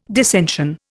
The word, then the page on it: dissension: Indian